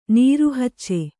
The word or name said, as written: ನೀರುಹಚ್ಚೆ